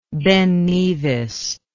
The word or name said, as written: Ben Nevis